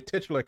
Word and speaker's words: titular: In the introduction to their characters, there's always the opposition in your testicular character. You have the opposition always attacking your testicular character. – Your titular character. – Yeah, I said it wrong the first time.